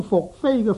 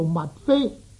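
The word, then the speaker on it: 宓妃: 最近呢，在一次闲谈里面呢，有朋友将福飞的福字呢读做物飞，这个读音呢显然是不正确的。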